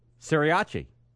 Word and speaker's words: Sriracha: Sriracha. – Sriracha. – Yeah, him. – Sriracha. – No. The first R is silent.